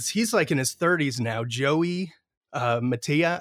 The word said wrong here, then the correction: Mantia: I think Mantia, is how you say it, Mantia.